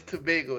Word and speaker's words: Tobago: So you were raised in the islands of Trinidad and Tobago. Is that right? Am I saying that right? – It's Tobago. It's a common misconception because it just looks like Tobago because of pronunciation. But yeah, it's Tobago.